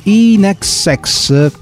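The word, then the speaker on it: INXS: continuando a esplorare i continenti, arriviamo ora ai nostri antipodi, cioè in Oceania e più precisamente in Australia, dove incontriamo gli Inexex. La Bertanza mi ha scritto anche l'esatta pronuncia, come se non avessi fatto radio 40 anni, l'Inexex, li conosco anch'io.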